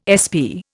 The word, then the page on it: SP: Indian